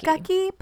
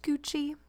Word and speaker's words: Gucci: It is under like Gukky, but it's pronounced Gucci.